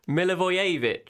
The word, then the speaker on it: Milivojevic: How do you say it? – Milivojevic. – Oh, sorry. I didn't do Milivojevic. I still didn't say it right.